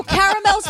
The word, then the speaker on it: caramel: No, caramel's the right way to say it. Caramel is wrong. – Oh, really? – It's spelled C-A-R-A-M-E-L. It's caramel. – Girl, what are we talking about today?